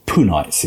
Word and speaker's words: Punites: we have the Tolaites and the Puarites, or Punites is how it's said.